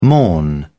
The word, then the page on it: morn: British English